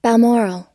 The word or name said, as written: balmoral